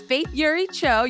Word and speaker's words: Faith Eury Cho: Oh, well, it's Faith Eury Cho. Eury is my middle name.